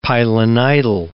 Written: pilonidal

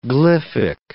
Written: glyphic

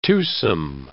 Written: twosome

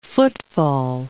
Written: footfall

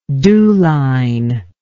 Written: dewline

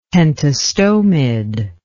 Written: pentastomid

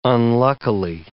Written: unluckily

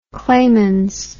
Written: klemens

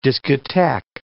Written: discotheque

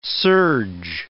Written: serge

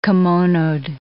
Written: kimonoed

